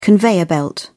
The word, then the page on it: conveyor belt: British English